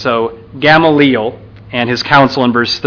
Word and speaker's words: Gamaliel: We get this man, Gamaliel. So I realize I've talked to people about this. I say his name wrong. I usually say Gamaliel. I want to say Gamaliel, because I think that's actually correct.